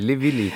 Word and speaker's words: Le Villi: Ich habe das Französisch ausgesprochen. Le Villi ist ja gar nicht so, oder? – Ist eine italienische Oper. Le ist der Pluralartikel. Le Villi, ganz...